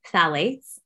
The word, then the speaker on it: phthalates: Perfumes, because they synthetic fragrances inherently contain a class of compounds called phthalates, which is also starts with a P. It's a silent P, I guess. And it's phthalates.